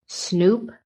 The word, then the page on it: SNOOP: American English